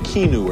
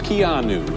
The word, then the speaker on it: Keanu: I was saying Keanu or something. It's Keanu.